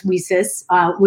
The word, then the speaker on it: WiCyS: We often go by our acronym, WiCyS, and as WiCyS, which is like pronounced like We Sisters.